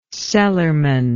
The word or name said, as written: cellarman